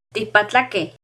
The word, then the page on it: Ticpatlaqueh: Náhuatl